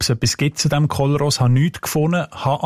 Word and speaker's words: Kollros: Kolros, K-O-L-L-R-O-S. – Genau, ich denke, vielleicht muss man eher Kolros aussprechen als Kolros, weil es nur ES hat.